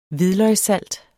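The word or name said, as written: HVIDLØGSSALT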